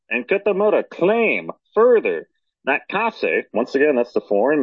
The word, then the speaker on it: Kase: Once again, that minister's last name is spelled K-A-S-E, Kase,